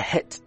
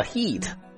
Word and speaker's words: hit: Till I hit the dance floor，在舞池里面啊开心的跳舞。 Hit the dance floor Dance floor, floor 在这里小小的卷舌一下， dance floor, hit 短元音不要读成了 heat， hit the dance floor, hit the dance floor.